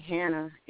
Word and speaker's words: Hanna: I do like I have a Facebook page, and if you put my name Barry Hanna in the Facebook,